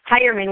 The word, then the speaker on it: Hierman: My full name is spelled H-O-L-L-Y. And my last name is Hireman, which is H-I-E-R-M-A-N. Again, that's H-I-E-R-M-A-N, Holly Hireman.